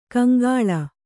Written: ಕೌಂಕುಳ್

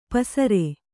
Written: ಪಸುರ್

